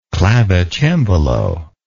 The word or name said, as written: clavicembalist